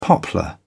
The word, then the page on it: poplar: British English